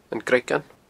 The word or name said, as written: Gnoigean